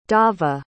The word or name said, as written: dava